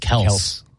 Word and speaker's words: Kelce: He said that his last name is actually pronounced Kelce. – But somebody called him Kelce and they just went with it.